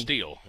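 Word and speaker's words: Stihl: Is it Still or Steel? – I don't know. It's S-T-I-H-L, and I probably mispronounced it, but somebody will correct me. – Steel, yeah.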